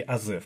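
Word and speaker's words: Азеф: Значит, правильно, наверное, все-таки Азев, потому что да.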